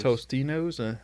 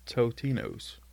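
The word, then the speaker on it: Totino's: Tostinos? Yeah, I think I've always said it wrong, too. It's Totinos.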